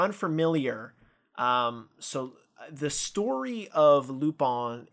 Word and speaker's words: Lupin: No, it's if you're unfamiliar, it's the word Lupin, pronounced Lupin.